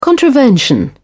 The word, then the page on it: contravention: British English